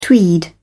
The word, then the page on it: tweed: British English